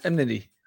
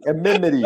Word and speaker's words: enmity: You could put George in and have him try to pronounce enmity. – Amenity. I just keep pronouncing it wrong the whole time.